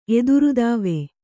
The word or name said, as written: ಎದುರುದಾವೆ